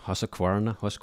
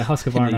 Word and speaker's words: Husqvarna: Well, we've been using the auto mowers from Husqvarna. That's how you pronounce them. – Yeah, Husqvarna. – In the US. – Yeah. – We have a different pronunciation for it over here, but we've been using the auto mowers from them, and initially just in the trial settings.